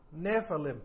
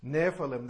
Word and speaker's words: Nephilim: La palabra traducida aquí, gigantes, es la palabra hebrea Nephalem, N-E-P-H-I-L-I-M, Nephalem, P-H en inglés se pronuncia como F, Nephalem, de ahí viene la pronunciación que les estoy dando. Esta palabra hebrea proviene del verbo Nephal, N-E-P-H-A-L, que significa caer, y que los eruditos afirman se puede traducir aquellos que han caído.